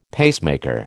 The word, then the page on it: pacemaker: American English